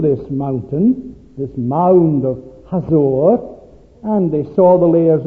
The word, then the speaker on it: Hazor: And so the Lord sold them again into the hand of Jabin, the king of Canaan, who reigned in Hazor. If you go on an extended tour of the Holy Land, you may be taken to Hazor. The guide will probably pronounce it Hatzor.